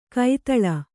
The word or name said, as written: ಕೈತಳ